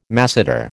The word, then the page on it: masseter: American English